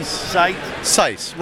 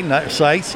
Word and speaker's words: Seiss: Seiss. Rhymes with nice. – Yeah. Okay, Seiss.